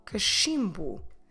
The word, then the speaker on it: cachimbo: C-A-C-H-I-M-B-O. Cachimbo. Como se escreve macho? M-A-C-H-O. Macho. Como se escreve brochura? B-R-O-C-H-U-R-A. brochura. Let's repeat these words again and focus on the pronunciation of the CH as a soft sh.